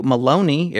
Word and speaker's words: Maloney: This is from Andrew Maloney. It rhymes with baloney.